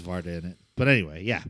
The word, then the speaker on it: Varda: And this is my first Varda movie. I think we're saying that right, by the way. – Yeah, I believe that's how it's pronounced.